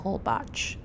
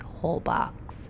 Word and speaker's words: Holbox: And then when we come back, we have a couple days here in New York City, and then we fly to Isla Holbox. That's how you actually pronounce it, but it looks like Holbox.